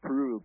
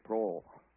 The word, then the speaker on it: Proell: P-R-O-E-L-L. – That's right. The German word, that's actually pronounced Prohl, but we English-speaking people would say Prohl.